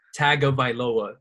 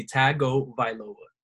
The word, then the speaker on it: Tagovailoa: No, in the current state of things, Miami has just drafted Tua Tagovailoa. – Whoa, hold up. Don't say it wrong. That's my quarterback. Don't disrespect him like that. – All right, educate us. – Tagovailoa. It's easy. Tagovailoa. – Tagovailoa.